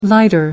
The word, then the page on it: lighter: American English